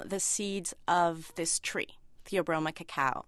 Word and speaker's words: Theobroma cacao: The scientific name is Theobroma cacao, which actually means food of the gods.